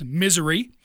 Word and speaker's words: Missouri: You go across Missouri, or as the appropriate pronunciation is, Missouri. As a kid from Illinois, I think I can say that.